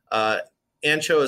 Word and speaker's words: ANXO: Like they were the first place I got to try Ancho. Ancho is spelled ANXO.